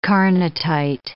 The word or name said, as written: carnotite